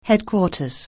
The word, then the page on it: headquarters: anglais